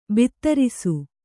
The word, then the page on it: ಭದ್ರಿಸು: kannada